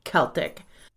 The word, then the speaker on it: Celtic: Oh, and yes, despite the Boston team, the proper way to pronounce it is as we're doing it the hard C, Celtic.